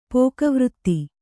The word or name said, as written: ಪೋಕವೃತ್ತಿ